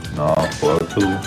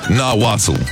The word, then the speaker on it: Nahuatl: In its original language, let's see if I can pronounce that. Nahuatl. – Nahuatl. Pronunciation. Come on. – Nahuatl. Yeah, cool. It's not spelled like that, trust me. Nahuatl.